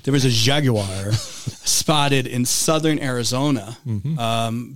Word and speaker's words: Jaguar: Jaguar. – You ever hear the British pronounce it? Because it's a Jaguar. – That's a British company, isn't it? – It is. – Jaguar.